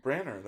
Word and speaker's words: Branagh: Would you call him Branner? – Branner. That's how you say his name.